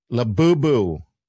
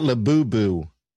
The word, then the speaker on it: Labubu: Labubu. Not Labubu. Labu. Like boo.